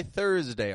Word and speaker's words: Thursday: So Wednesday, followed by Thursday, our bonus word of today. Both are spelled with an S, and that S is pronounced as a Z, a Z kind of sound.